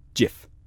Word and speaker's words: GIF: So according to Steve, you should say it like gin. So it's GIF, the soft G of gin. not the hard G of What does the Godzilla.